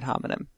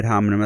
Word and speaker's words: homine: And there's also the idea of the opposite of ad hominem. I think it's called a pro homine, H-O-M-I-N-E. How would you pronounce that? – Homine. – Pro homine.